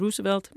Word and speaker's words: Roosevelt: Og så tog vi ned på Roosevelt, som var natklubben, altså det udtaltes, burde have været udtalt Roosevelt, men det vidste vi ikke, så det hed Roosevelt.